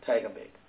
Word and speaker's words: Tygerberg: In the city of Tygerberg, if I'm pronouncing it properly. Yeah, Tygerberg.